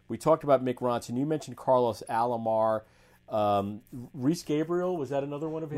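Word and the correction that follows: Reeves Gabrels: Reeves Gabriels, I guess is how you say his name.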